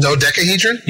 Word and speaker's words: dodecahedron: Dodecahedron? – Yeah, that's what it is.